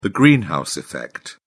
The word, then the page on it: greenhouse effect: British English